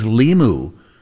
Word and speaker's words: Limu: What is Limu? But it rhymes with Emu, but who cares about an Emu?